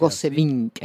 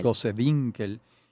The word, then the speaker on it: Gosewinkel: Y en la lista de tus maestros tengo a Fedora Alemán, a Gonzalo Castellanos, a Modesta Bor, a Ángel Sauce, a Ruth Goswinkel, no sé si se pronuncia Gosewinkel. – Así, Gosewinkel, y Alberto Grau.